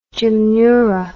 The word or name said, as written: gymnura